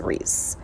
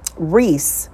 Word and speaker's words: Reese: My name is Teresa Reese. That is my birth name. Reese is spelled like the candy.